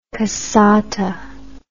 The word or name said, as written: cassata